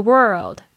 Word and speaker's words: world: If you wish the world，the world 就是指世界，大家可以注意一下你们 world 的发音哦。通常我听到很多的学生们，这个 world 跟那个字。那个 word，呃，搞不清楚，因为少了这个翘舌音 l 的声音哈。